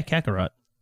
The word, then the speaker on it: Kakarot: Yeah, Kakarot.